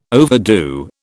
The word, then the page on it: overdo: British English